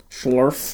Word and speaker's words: Schlerf: I'm going to talk about the lead writer of Mass Effect Andromeda, Chris Schlerf. – Schlerf. – That's a name. – Well, S-C-H-L-E-R-F.